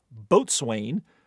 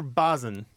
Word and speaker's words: boatswain: which landlubbers mispronounce as boatswain, or what rather economical five-letter word that is pronounced the correct way. – I usually get it wrong. I'm going to say it wrong. Is it bosun or bosun? – Bosun. – It's bosun. Bosun. Yeah, bosun is fine. But you don't want to say boatswain.